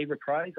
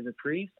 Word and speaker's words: Reprise: It's not incorrect. I do not personally say Reprise. I say Reprise, but the word is not incorrect. It rhymes with lies, and that's what we went with."